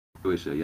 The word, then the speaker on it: Shueisha: It's single player, so, and it is distributed by Shueisha Games. I'm sorry, I butchered that. – Shueisha, yeah.